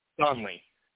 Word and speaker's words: Donley: Donley. – Spell it, please. – D-O-N-L-E-Y, one word.